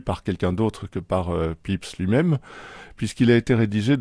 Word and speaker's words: Pepys: Samuel Pepys, qui se prononce Pepys en réalité, mais ça s'écrit P-E-P-Y-S,